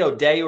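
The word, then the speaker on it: O'Dea: That guy Joe O'Dear. – O'Day, yeah. – Is that how you say it? O'Day or Deer? Yeah, O'Day.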